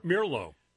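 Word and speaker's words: Meerlo: It's M-E-E-R-L-O. – A weird name. – Mirlo.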